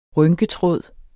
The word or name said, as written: rynketråd